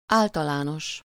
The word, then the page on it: général: France; [ʒe.ne.ʁal]